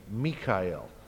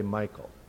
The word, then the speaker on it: Mikael: And that's the usual European pronunciation of that name, Mikael, as in Mikhail Gorbachev. So Mikhail Gorbachev is who is like God. He has a religious name. I don't know if he himself is religious. And it's only Americans that mispronounce all these names. So we're the ones who say Michael. Every biblical name, we mess up.